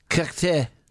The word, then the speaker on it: Cocteau: Cocteau.